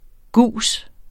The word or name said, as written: gus